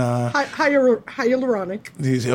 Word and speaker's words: hyaluronic: There's a mochi toner that's been really fun, and the hyaluronic acid that gets it nice and hyaluronic.